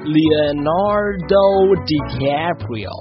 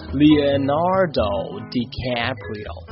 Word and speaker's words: Leonardo DiCaprio: 原来呀，是因为他的英文的原名呢是念作来和我一起念，念作 Leonardo DiCaprio。再来一遍 Leonardo DiCaprio，他呢我们这边是翻译成莱昂纳多迪卡普里奥，莱昂纳多迪卡普里奥，可是在台湾他是翻成李奥纳多 Leonardo 李奥纳多，所以呢他叫做小李子。1分钟了，就这样 See you next time。